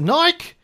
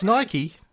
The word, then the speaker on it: Nike: And they were asking him how to pronounce Nike. Is it Nike? And he's like, no, it's Nike. Hunter was saying, yeah, but how do you pronounce B-I-K-E?